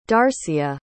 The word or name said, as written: darcia